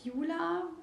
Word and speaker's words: Juula: Genau, also ausgesprochen wird Juula, aber mit zwei U's geschrieben.